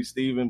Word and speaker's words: Stephen: Everybody calls me Stephen now because it's spelled the same way. But it's actually really Steven, but just depending on that's a good name.